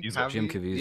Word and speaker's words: Jim Caviezel: Jim Caviezel.